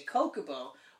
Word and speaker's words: Chocobo: Yeah, my argument was, my name is spelt. I say, so I would say Kokobo. But chocolate is spelt C-H-O, yeah. – So I can see why people would say chocobo. It's wrong. Completely wrong. It's Kokobo. It always has been. It always will be.